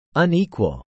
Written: Unequal

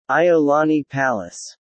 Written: Iolani Palace

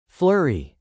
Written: flurry

